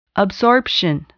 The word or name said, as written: absorption